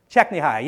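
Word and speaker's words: Csikszentmihalyi: Okay, well, that means that all of you can tell me how to say his name. Go ahead. Chechnyai, yes, that's exactly right.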